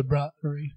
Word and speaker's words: Bruery: We keep saying the brewery. It's spelled B-R-U-E-R-Y. – Yeah, that's why it's the brewery.